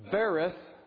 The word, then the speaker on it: berith: And so the word berith, as we're told it's spelled, B-E-R-I-T-H, here in Malachi 2:14, berith, covenant, is the idea of an agreement.